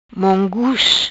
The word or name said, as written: Mongus